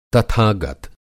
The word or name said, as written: तथागत